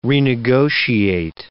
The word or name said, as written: renegotiate